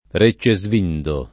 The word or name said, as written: Reccesvindo